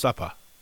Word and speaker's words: supper: Supper, often pronounced suppa, that's the third meal of the day.